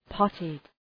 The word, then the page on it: potted: ‘pɒtıd